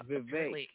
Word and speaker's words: Vivek: Yeah, Vivek. Yeah, he pronounced it like cake.